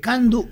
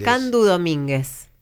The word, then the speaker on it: Candú Domínguez: ¿Cómo se dice? ¿Candu o Candú Domínguez? – No, Candú Domínguez.